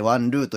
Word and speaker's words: 王露: ええー、 1 月 14 日まで行われてるんですけれども、えー、中国出身の写真家さんのワン・ルーさん、えっと、これ、王王様の王に、梅雨と書いてワン・ルーと読むんですけど、はい、このワン・ルーさんによる、フローズン・アー・ザ・ウィンズ・オブ・タイムという、まあ、写真展をご紹介します。